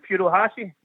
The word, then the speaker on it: Furuhashi: Uh, but my other, my other point is about uh, is it, I can't, how to say his name is Furuhashi. – Yep, he's coming.